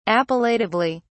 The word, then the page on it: appellatively: British English